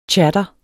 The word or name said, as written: tchader